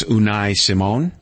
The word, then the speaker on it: Unai Simón: I think I'm going to pronounce this right, but it's Unai Simón.